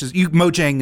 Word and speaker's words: Mojang: I think if you're pronouncing it in Swedish, Mojang is fine.